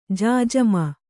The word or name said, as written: ಜಾಜಮು